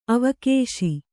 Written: ಅವಕೇಶಿ

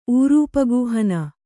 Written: ಊರೂಪಗೂಹನ

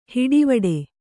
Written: ಹಟವಿಡಿ

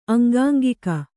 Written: ಅಂಗಾಂಗಿಕ

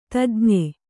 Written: ತಜ್ಞ

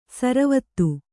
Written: ಸರವತ್ತು

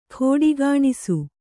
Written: ಖೋಡಿಗಾಣಿಸು

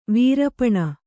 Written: ವೀರಪಣ

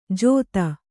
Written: ಜೋದ

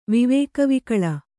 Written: ವಿವೇಕವಿಕಲ

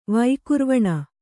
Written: ವಿಕುರ್ವಾಣ

